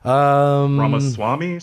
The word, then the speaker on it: Ramaswamy: Ramaswamy? – Yeah, like that? Yeah. You said it.